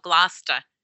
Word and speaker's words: Gloucester: When I first moved here, I pronounced it incorrectly. It's spelled like it sound. It should be like Gloucester, but they call it Gloucester, north of Boston.